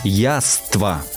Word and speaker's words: яства: Ну, какие явства? Яства. Да, спасибо вам большое, что вы меня поправили.